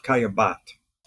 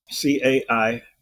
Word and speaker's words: Caillebotte: They had a wealthy friend who was also a wonderful artist himself, Gustav Caillebotte, Caillebotte.